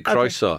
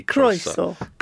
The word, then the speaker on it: croeso: I'll say it for you, croeso. Okay. That's what you would say to me, croeso. Croeso, yeah.